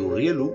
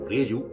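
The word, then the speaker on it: Urriellu: El Urrielu, o Urrieyu, según se escribe,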